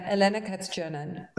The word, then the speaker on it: Elena Kats-Chernin: We're going to have a listen to, and how did you pronounce the name again? – Elena Elena Kats-Chernin.